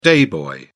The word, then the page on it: day boy: British English